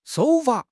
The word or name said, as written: 手法